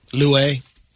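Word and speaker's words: Luai: His name is Louay. – Nah, I don't think you said that right. – Louay. – Louay. – He's going to sing the song. – L-U-A-I. L-U-A-I.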